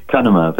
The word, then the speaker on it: Cunnama: Cunnama, is that right, doctor? – Cunnama, but that's fine.